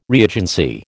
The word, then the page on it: reagency: American English